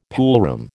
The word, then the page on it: poolroom: American English